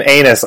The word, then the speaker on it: Aenys: And there's just no other way of getting around the pronunciation of Aenys in this. So. – Nope. – Okay, I thought his name was Aenys. And you guys are just throwing me right off. I'm like, wait a minute. Have I been saying it wrong this whole time? – No, we're going to be saying anus a whole lot this episode.